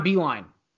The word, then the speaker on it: Beilein: John Beilein. That's how you pronounce it.